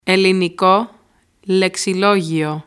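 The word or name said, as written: Ελληνικό Λεξιλόγιο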